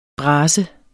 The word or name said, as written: bras